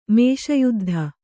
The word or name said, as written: ಮೇಷಯುದ್ಧ